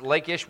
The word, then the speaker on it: Lachish: Uh, we talked about Ashkelon last week, and we talked about Gath last week, and I'd planned to get to Lachish uh, it's a lot of times it's pronounced Lakish. We pronounce it Lakish, kind of I guess in the English pronunciation. Um, it's Lakish, and actually they have that really. It's more like Lachish if you were going to say it in Hebrew. Um, but it's everybody pronounces it like that.